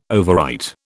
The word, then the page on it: overwrite: British English